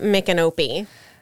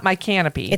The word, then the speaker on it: Micanopy: I call it Micanopy. – Okay. I've heard Micanopy. It's not my canopy.